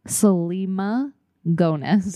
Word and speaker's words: Selena Gomez: Say her name right or don't say it at all. – Selena Gomez. – Thank you.